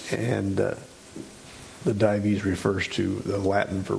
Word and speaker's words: Dives: there's a Latin word, and I don't know if it is pronounced with two syllables or one. D-I-V-E-S is his name. Would it be Dives? Dives? Okay, typically.